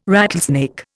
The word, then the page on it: rattlesnake: Indian